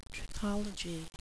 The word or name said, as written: trichology